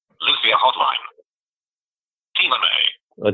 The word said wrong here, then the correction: Tina Mai: I didn't quite pronounce it right. It's actually Tina Mai, but we'll forgive it.